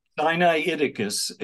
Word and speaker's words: Sinaiticus: And how do you say the second word? – Sinaiticus. It's so Sinai.